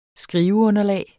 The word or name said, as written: skriveunderlag